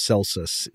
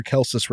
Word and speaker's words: Celsus: I think sometimes maybe pronounced Celsus, C-E-L-S-U-S. But I think those Roman C's are supposed to be a hard K sound. So I'm going to say Celsus.